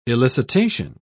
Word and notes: elicitation: British English

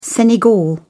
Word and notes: senegal: British English

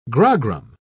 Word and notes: grogram: British English

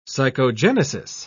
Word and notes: psychogenesis: British English

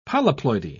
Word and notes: polyploidy: British English

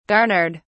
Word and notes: garnered: British English

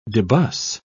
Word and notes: debus: British English

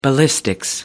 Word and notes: ballistics: American English

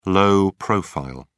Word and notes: low profile: British English

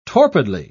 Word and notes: torpidly: British English

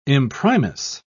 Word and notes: imprimis: British English

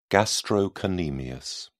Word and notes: gastrocnemius: British English

